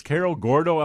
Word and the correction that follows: Cerro Gordo: Illinois? – Cerro Gordo, Illinois, right near Decatur.